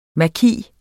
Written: maki

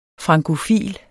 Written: frankofil

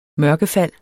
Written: mørkefald

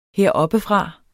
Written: heroppefra

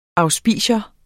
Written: auspicium

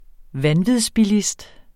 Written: vanvidsbilist

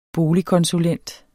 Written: boligkonsulent

